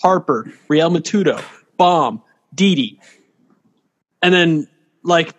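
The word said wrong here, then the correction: Realmuto: Real Muto, mate. What is it with you and names? Yeah. Real Muto.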